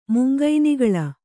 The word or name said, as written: ಮುಂಗೈನಿಗಳ